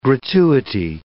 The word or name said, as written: gratuity